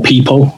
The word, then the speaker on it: Pipl: And that tool uses data from lots of different sources, a big one being PIPL, or People, as some people pronounce it,